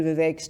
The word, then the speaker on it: Vivek: So Mr. Ramaswamy, who is running for president, his first name is V-I-V-E-K, and in one of the debates he said, to help with the pronunciation of his name, Vivek rhymes with cake.